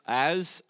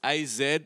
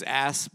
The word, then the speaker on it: Az: My name's Az, A-Z is how it's spelt. Some people go, your name's Ass, but anyway, no, it's not. It's Az.